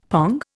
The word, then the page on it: pong: Indian